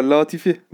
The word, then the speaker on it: Latifi: Latifi.